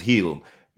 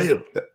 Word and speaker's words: Hield: And just slight correction, the man's last name is Heal. You got the producer over there, pronouncing. What's the last name again? Say it again. – Hield.